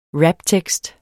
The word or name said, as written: raptekst